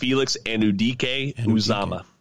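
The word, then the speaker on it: Anudike-Uzomah: Or it's pronounced Felix Anudike Uzomah.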